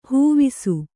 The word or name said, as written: ಹಾವುಸೆ